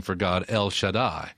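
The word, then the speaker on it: Shaddai (שַׁדַּי): Almighty is Shaddai.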